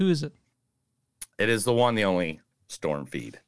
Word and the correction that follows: Reid: Reed. It's Reed. I fucked up there.